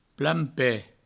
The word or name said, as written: plan-ped